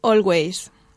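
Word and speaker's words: Alvvays: Entonces, se llaman Always. Pero no se escriben Always. Se escriben con dos Vs, no con una W, ¿vale?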